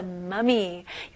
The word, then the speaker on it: mummy: The archaeologist discovered the mummy. 여기서는 mummy가 엄마가 아니고요. 미라지요. 네, 발음은 아주 살짝 차이점이 있어요. 엄마라고 할 때 귀엽게 사랑스럽게 mommy라고 하고요. 여기서는 mummy,